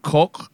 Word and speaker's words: Koch: Leeds added defender Robin Koch from Freiburg, or Koch, as it may or may not be pronounced in Germany.